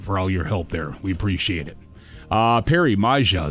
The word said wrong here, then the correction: Maja: Xander, Maya, the J is silent. Sorry, Maya.